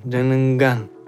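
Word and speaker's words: njenengan: В яванском будет н джененган, джененган. И в начале стоит буква «н», которая тоже не произносится. И получается, что мы произносим просто джененган.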